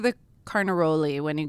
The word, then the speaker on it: Carnaroli: Canaroli. No, Carnaroli. – Carnaroli. You're faking me out. – Sorry. – Canaroli, Carnaroli. – And we're not rolling our R's.